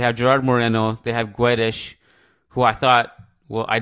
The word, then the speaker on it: Guedes: Guedes. The U you don't pronounce. It's like Guedes. You know, like que, you don't say que.